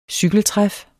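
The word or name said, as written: cykeltræf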